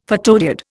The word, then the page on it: photodiode: Indian